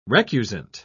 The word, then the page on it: recusant: British English